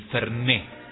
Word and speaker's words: Fernet: Ay, pero queda tan bien el Fernet, con acento en la última E, porque es aguda y termina en vocal, yo acento.